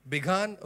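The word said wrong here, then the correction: Bigthan: okay. No, Bigtan, I think. It's Bigtan. He has a T there.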